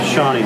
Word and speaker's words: Shawny: Shawnee. – How do you spell that? – S-H-A-W-N-Y.